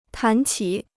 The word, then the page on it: 谈起: tán qǐ